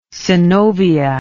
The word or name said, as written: synovia